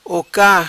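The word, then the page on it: Oca: patua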